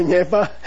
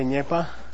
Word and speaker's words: Nyepa: Say Nyepa. Nga. Those of you who can't see, NYEPANGA. Say Nyepa.